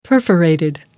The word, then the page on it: perforated: American English